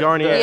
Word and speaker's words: Garnier: I don't know if I'm saying it right. The Palais Grenier. – The Garnier.